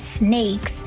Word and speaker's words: Snayx: And then coming back to Reading once again, I've got some music from I think you say their name as Snakes. It's spelled S-N-A-Y-X.